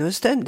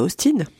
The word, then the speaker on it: Austen: Ça fait une dizaine d'années que j'avais envie d'écrire autour de Jane Austen. D'ailleurs, c'est chouette que vous disiez Austen et pas Austin, parce que ça s'écrit avec un E.